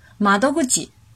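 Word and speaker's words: 窓口: 下面来看训读的发音，ku ji。举个例子，窗口，马多古吉，马多古吉，马多古吉。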